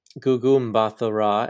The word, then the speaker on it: Gugu Mbatha-Raw: Now, Gugu Mbatha-Raw as Ravonna Renslayer. I'm hoping I pronounced that correct. – You did. You got it right.